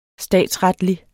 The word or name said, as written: statsretlig